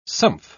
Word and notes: sumph: British English

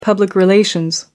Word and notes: public relations: American English

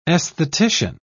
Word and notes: esthetician: British English

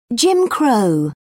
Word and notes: jim crow: British English

